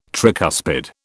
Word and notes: tricuspid: British English

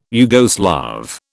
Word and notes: Yugoslav: British English

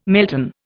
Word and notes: melton: Indian

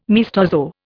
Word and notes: maestoso: Indian

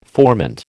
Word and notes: formant: American English

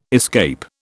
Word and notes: escape: British English

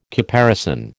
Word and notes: caparison: American English

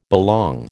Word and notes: belong: American English

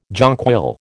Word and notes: jonquil: American English

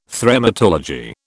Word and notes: Thremmatology: British English

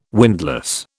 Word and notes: windlass: British English

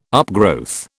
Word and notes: upgrowth: British English

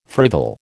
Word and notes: frivol: American English

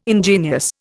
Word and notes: ingenious: Indian

